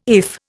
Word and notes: IF: Indian

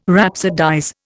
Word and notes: rhapsodize: Indian

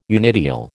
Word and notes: unideal: American English

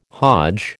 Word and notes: Hodge: American English

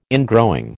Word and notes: ingrowing: American English

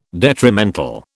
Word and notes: detrimental: British English

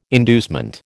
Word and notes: inducement: American English

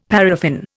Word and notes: paraffin: Indian

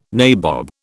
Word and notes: nabob: British English

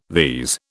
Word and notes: these: British English

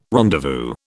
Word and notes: rendezvous: British English

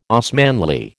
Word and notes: Osmanli: American English

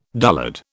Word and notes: dullard: British English

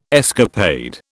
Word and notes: escapade: British English

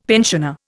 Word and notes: pensioner: Indian

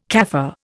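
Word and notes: café: Indian

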